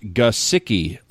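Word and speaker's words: Gesicki: This comes from Thomas Roberts, and Thomas Roberts actually, the heading on this says Mike Gasicki, and it says, "Come on, guys, it's pronounced Gasicki, like kind of like the word gun, then the word sick emphasized and followed by the word key.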